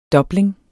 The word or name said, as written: DOBLING